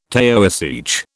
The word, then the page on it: Taoiseach: British English